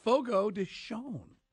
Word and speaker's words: Fogo de Chão: But apparently, Carl, it is pronounced Fogo de Chão. So if you're pronouncing it like my kid's sister, we're going to go to Fogo de Chão. You're saying it the wrong way.